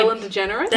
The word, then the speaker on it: Ellen DeGeneres: Ellen DeGeneres? – There we go.